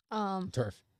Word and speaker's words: TERF: There was a guest who was also considered a TERP. – TERF. – That's what I said. I mispronounced my last letter.